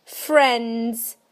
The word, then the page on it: friends: British English